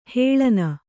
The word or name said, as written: ಹಾಲುಣ್ಣಿ